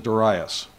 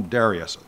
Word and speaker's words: Darius: So, during the first year of, now, I use a long A, some people use a short A. I always learned this fellow's name is Darius. Some people like to call him Darius. You know, whichever you prefer, doesn't matter, same guy.